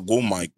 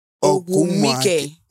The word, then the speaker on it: Ogwumike: Ogwumike. – Stop saying it like that. – And Nika, Ogwumike. – Ogwumike. – So it's... – Nika Ogumike. That's how you're going to pronounce it.